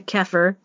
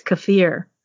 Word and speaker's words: kefir: And please don't try and correct my pronunciation to kefir. That's not how it's pronounced unless you're an American and haven't bothered to learn how to pronounce it. It's kefir.